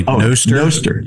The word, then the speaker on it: Nostr: It's Nostr. Is that how you pronounce it? – Yeah, like Nostradamus. So probably Nostr.